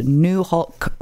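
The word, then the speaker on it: Nuxalk: I really, really want you to check out New Holc Radio in Bella Coola, and the way that is spelt is N-U-X-A-L-K N-U-X-A-L-K, and it's pronounced New Holc Radio,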